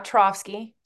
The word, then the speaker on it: Taravski: So for anyone listening that has not met Renata, I'm going to butcher your last name. Taravski. Did I say it right there? – Close enough. Taravski.